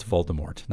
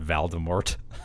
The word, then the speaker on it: Voldemort: And anyway, it's Voldemort, not Valdemort. – Whatever. – Maybe Valdemort's his cousin from the hills or something. Hey, Voldemort, okay, what's up? It's me, Valdemort. It's like Scooby and Shaggy. Valdemort's like, hey, everybody, I'm going to kill some witches and goblins. – Isn't now. – It's spelled Valdemort. – What? – Isn't it spelled Voldemort? – I think it's Voldemort. V-O. Isn't it? – It's all right.